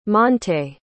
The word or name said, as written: montae